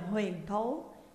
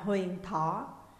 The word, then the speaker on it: Huyền Thố: Long quyết trừng huyền thố, cộng ghi sườn am sang. Huyền Thố cũng đọc là Huyền Thỏ, tên một quận ở Bắc Trung Quốc gần các tỉnh Sát Cáp Nhĩ và Nhiệt Hà, đây là chỉ Liêu Đông.